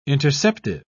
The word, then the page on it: interceptive: British English